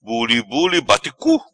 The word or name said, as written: Bulibuli Bate Cu